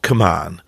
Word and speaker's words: kmuan: There's an old acronym I sometimes use when I coach, and it's called Come On. It literally sounds like Come On, as if to say, Come On, keep going. But the meaning is, keeps me up at night, and it's spelled K-M-U-A-N.